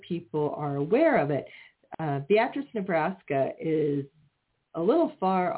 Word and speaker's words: Beatrice: Now, it is located in Beatrice, Nebraska, and it is pronounced Beatrice, which is actually the same way my great-great-aunt's name is pronounced, so I feel a kinship for it right there.